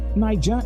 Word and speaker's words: Naija: Limex is spelled L-I-M-E-X TV. Limex TV or Niger TV. Niger is spelled N-A-I-J-A TV.